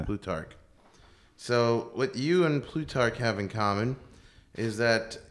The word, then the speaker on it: Plutarch: Plutarch? Am I saying it right? – Well, they have it. Yeah, no, I think you're right. Plutarch, because they have it down, broken down.